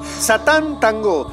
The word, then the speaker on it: Satán Tango: Pero con acento en la última O. Satán Tango.